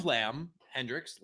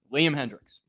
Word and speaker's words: Liam Hendricks: You need to listen to Liam say his name. It's Liam Hendricks. – Lamb Hendricks. – It's not Lamb.